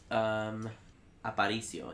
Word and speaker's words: Aparicio: I'm going to say this wrong. Let's Aparicio. – Aparicio. Yeah, that's pretty right.